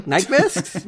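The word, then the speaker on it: Nyqvist: Nyquist.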